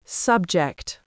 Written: subject

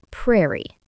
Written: prairie

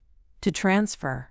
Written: to transfer

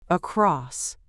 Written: across

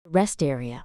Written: rest area

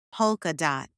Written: polka dot

